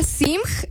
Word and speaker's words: Simg: Ana Simg. Ana S-I-M-G. Que mi apellido es chino, ¿tú crees? – Sí, ¿tienes familiares chinos?